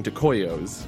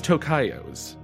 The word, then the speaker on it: Tocayos: Last episode, I was pronouncing Tocayos wrong. I was saying Tocoyos. It is Tocayos.